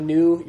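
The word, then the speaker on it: New (ν): And for your first couple weeks of reading Greek words, every time you come to a new, you're going to pronounce it like a V, and you just got to stop and remind yourself, nope, that's a N. N. New, as in new.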